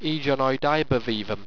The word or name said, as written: ejanoidevevam